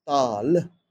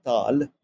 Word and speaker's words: tala: And the other element of this music is taal, or tala, as it is spelled in English, taal, which means rhythm, tempo, the time cycle.